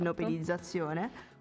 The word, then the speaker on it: ipnopedizzazione: ipnopedizzazione. Ho detto bene? Sì, penso Ipnopedia, sì. quindi esatto. ipnopedizzazione,